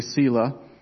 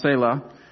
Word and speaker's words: Selah: Those of us here in Eastern Washington tend to pronounce it wrong. We say Selah because that's a town near Yakima. But in Hebrew, it was pronounced Selah.